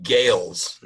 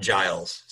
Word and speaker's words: Gales: It's Eric Gales, not Giles. So I did have it wrong. So I'm glad I looked it up. It's Eric Gales, G-A-L-E-S, and Joe Bonamassa.